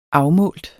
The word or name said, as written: afmålt